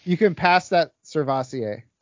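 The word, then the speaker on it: Sirvocea: Servosea Dennis? – Servassier. – Like Crevassier? – Kind of. Yeah, it's pronounced Servassier Dennis.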